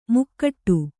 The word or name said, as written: ಮಕುಟ